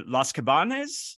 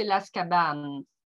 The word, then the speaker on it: Las Cabanes: Yeah, you pronounce it a bit maybe like in Spanish. – I do. – The S in French is silent. So I say Las Cabanes.